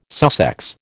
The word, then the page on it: Sussex: American English